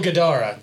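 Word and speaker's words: Guidara: Will Gadara. There we go. He said it right.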